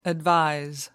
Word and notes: advise: American English

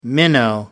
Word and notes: minnow: American English

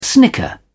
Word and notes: snicker: British English

